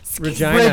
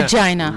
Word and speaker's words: Regina: Regina. – Regina.